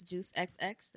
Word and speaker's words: Juice XX: I go by the name Juice, Juice XX. The X is silent because you're going to give me two kisses after you hear me sing.